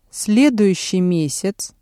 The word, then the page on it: следующий месяц: Russian